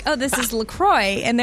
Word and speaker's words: La Croix: And they even say on their website, it's definitely pronounced La Croix. And if you ever get confused about it, just remember it rhymes with enjoy. So enjoy your La Croix.